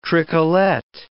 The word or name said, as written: tricolette